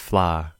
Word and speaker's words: flour: I remember I was reading a story and the word was F-L-O-U-R. And I said flower. – Mm-hmm. – And the whole class laughed at me. They were like, that's not how it's pronounced. In Singapore, it's pronounced fla.